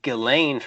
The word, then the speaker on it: Ghislaine: But that's not how you say it. It can't. That's too perfect if she actually had the name Jizz in her name. That would be too perfect. So it's supposed to be Ghislaine, from what I understand.